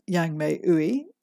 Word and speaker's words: Yang-May Ooi: You can find it by searching for my name, Yang-May Ooi, and I'll spell that for you Yang-May, Y A N G hyphen M A Y, Ooi, O O I.